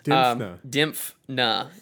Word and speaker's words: Dimphna: Dimphna, yes.